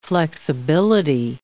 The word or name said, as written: flexibility